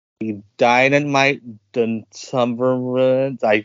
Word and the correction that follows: Duumvirate: It was the Dynamite Duumvirate Tag Team Title Tournament. – Duumvirate.